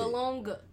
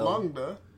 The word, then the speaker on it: bologna: I never heard it pronounced like that. That's how you spell baloney, though. – I thought it was Bologna. Like, B-O-L. – Bologna.